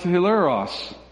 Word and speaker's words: hilaros: it's a Greek word that's pronounced hilaros. Does that sound like anything? Yeah, it's the exact same word that we get hilarious from.